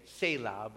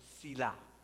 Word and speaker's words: Selah: Some people say Selah, but I actually checked it out online, and it was a Hebrew pronunciation, Selah, Selah, right?